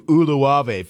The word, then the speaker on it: Uluave: He starts it with a U, Uluave. Found the pronunciation guide so I could actually say it right today.